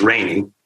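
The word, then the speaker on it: Reynie: Probably most, though not all, of people who read the book in print think of his name as Rainey. And then now there's the television show, and his name is pronounced Rainey on the show. So what I would like to do is to assure listeners and readers that Rainey will answer to either happily, and he doesn't have a preference of which way to pronounce his name.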